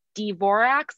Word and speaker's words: Dvorak: But anyway, everybody always called her Dvorak. Um, and that did become the common pronunciation and what she went by.